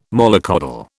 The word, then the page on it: mollycoddle: British English